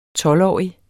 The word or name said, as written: tolvårig